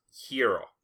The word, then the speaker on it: gyro: and I'm going to pronounce it gyro, and I don't care if it's actually pronounced hero.